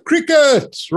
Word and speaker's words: Cricut: Martin Kruger is the head of Middle East and Africa at Crickutz, spelled C-R-I-C-U-T, but pronounced as in Crickutz, right?